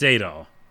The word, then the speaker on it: Adol: It's Adol. It's definitely Adol.